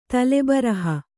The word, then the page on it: ತಲೆಬರೆಹ: tale bareha